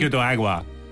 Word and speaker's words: Pico de Agua: There was a ride called the Splash-O-Rama, Pico de Agua. How would you say that in Spanish? – Pico de Agua. – Pico de Agua.